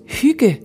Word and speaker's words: hygge: What is hygge? Pronounced hygge, the Danish word certainly doesn't sound like it's spelled, but we promise you we haven't made it up.